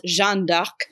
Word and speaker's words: Jeanne d'Arc: If you're gonna be stuffy and French about it, her name is pronounced Jeanne d'Arc, which is spelled differently because French.